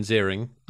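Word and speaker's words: Ziering: Finn is Ian, pronounced Iron Zeering.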